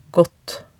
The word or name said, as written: gaatt